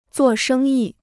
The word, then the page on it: 做生意: zuò shēng yì